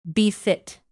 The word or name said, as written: befit